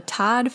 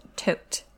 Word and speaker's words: Todt: You may know them as the Todd family, but actually, apparently, it's pronounced Tote.